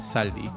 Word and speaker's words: salty: Se escribe salty, se pronunciaría sally, salty.